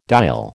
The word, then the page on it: dial: American English